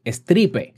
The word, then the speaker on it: Stripe: Se escribe e S, se escribe S-T-R-I latina P-E, Stripe. Así se escribe, bueno, así se pronuncia en español.